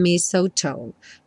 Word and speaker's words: mistletoe: And mistletoe. Mistletoe. Tá? Não se confunda com esse T-L-E aí. Mistletoe.